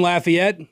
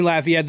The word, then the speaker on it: Lafayette: Good afternoon, Lafayette. I said it right this time. Good afternoon, Lafayette.